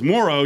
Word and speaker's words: Moreau: The way you pronounce it, according to his family, is Moreau.